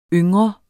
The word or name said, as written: ung